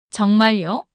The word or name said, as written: 정말요?